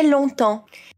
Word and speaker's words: longtemps: E aqui, a gente tem dois sons nasais na palavra longtemps. O on e o en. On em long e en em temps. Para a gente fazer on, a gente faz um som bem fechado. A boca fica bem redonda, igual quando a gente vai falar a letra O.